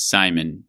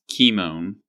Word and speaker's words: Cimon: You might be tempted to pronounce it as Cimon, but that's wrong. It's spelled with a kappa in the Greek. So I am going to be insistent that it is Cimon for this episode.